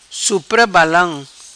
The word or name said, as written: Supra Balam